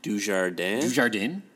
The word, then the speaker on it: Dujardin: Starring Jean Dujardin.